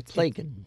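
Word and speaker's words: Plakun: It's Plakin. – Plakin, and it rhymes with bacon. – Plakin, P-L-A-K-I-N? – K-U-N. – K-U-N.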